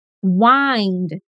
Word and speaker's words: wind: Bom, a outra pronúncia é wind. Repete. Wind. O D final é bem sutil mesmo.